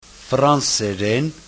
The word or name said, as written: franseren